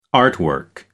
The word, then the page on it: artwork: American English